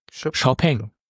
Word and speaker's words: Xiaopeng: Nee, sag mir nur, wie spricht man es aus? – Xiaopeng. Okay. So hat es mir jedenfalls der Kollege von Xiaopeng erklärt. Gut. Hört sich auch schöner an als Xpeng.